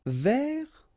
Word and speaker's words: vers: Vers, so you don't pronounce the final S, means towards or about.